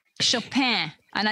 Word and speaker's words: Chopin: So don't say the N, it's an. – Okay. – Chopin. And I know that's hard for non-native speakers to make that sound, but that's the sound.